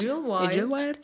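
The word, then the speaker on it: Idyllwild: Und zwar in der kalifornischen Stadt, oh, so, ich will jetzt immer nicht falsch aussprechen, aber ich glaube Idlewild. Idle, Idylwild. – Idlewild.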